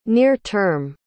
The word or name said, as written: near-term